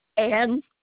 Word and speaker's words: Ann: Ann Murray. – Is that spelled with an E or just A-N-N? – A-N-N.